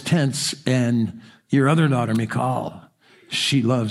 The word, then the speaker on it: Michal: Some people say Michael. I think in the Hebrew it would probably be pronounced Michal with one of those guttural H sounds.